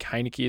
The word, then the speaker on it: Heinicke: And if you sort of wanted to go Taylor Heinicke, I think Heinicke is the right word, right pronunciation of his name, and Alex Smith is a stack later on.